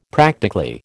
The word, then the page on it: practically: American English